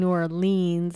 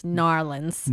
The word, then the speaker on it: New Orleans: We kept saying New Orleans, and it's Narlins.